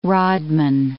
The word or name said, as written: rodman